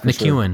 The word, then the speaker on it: McKeown: McKeown.